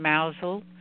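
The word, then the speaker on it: Mousehole: They live in Mousel, which is spelled Mouse Hole actually.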